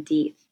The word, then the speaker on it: Death: And I have to tell you, this was one of the original things that caught my eye about this story, because his last name is spelled Deeth, D-E-A-T-H, which is, it's very strange.